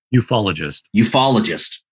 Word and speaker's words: ufologist: We have with us, a UFOologist. UFOologist, I'm saying that correctly. – No, I'm saying it incorrectly. – Ufologist.